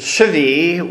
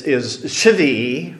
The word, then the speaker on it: shvi: But the word for seventh is shvi, which the syllable ends with a vowel before it, and so it's a V.